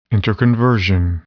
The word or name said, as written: interconversion